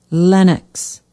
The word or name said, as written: Lennox